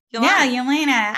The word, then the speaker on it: Yelena: Yelena. Ah, I love her. – Why do I keep saying it wrong? – Yeah, there's no O.